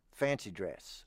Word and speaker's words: fancy dress: Am I saying it right? – Yes. – Fancy dress. – Yes. Yes. – Because I was saying fancy dress or something like that. I had the accent on the wrong...